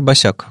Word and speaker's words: Басак: Артур Басак. Э, надеюсь, я правильно произношу ударение. Если нет, извини, Артур. – Артур Басяк. – Да? – Все очень просто.